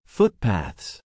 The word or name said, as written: footpaths